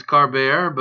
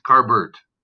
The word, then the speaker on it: Carbert: Sometimes it's Carbert, but most of the time it's Carbert.